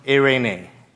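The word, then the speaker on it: eirene (εἰρήνη): In Hebrew, it's shalom. And in Greek, they pronounce it eirene.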